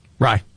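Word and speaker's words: Rai: Yeah, Aaron Rye. Is that how you say his name? – Rye.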